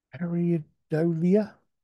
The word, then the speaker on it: pareidolia: Yeah, Pareidolia. – Pareidolia.